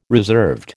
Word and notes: reserved: American English

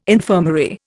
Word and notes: infirmary: Indian

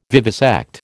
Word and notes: vivisect: American English

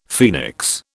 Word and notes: phoenix: British English

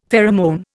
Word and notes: pheromone: Indian

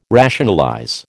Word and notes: rationalize: American English

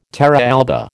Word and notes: terra alba: American English

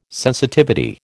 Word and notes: sensitivity: American English

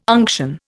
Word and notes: unction: Indian

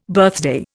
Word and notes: birthday: Indian

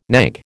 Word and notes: knag: American English

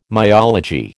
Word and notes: myology: American English

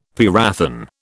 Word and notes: parathion: British English

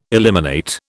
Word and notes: eliminate: British English

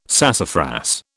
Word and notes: sassafras: British English

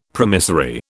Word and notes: promissory: British English

